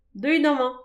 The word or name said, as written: deuit aman